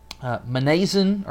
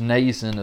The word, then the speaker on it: Mnason: And some of the disciples from Caesarea went with us, bringing us to the house of Mnason, or I think probably just supposed to be pronounced with an N. Is that what you think? – Probably. – I think it's just Mnason of Cyprus.